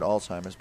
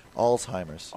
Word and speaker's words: Alzheimer's: Even though there's no T in the word Alzheimer's, but so, yes. So help me pronounce that. – Alzheimer's. – Alzheimer's. – Yeah, just like it's written.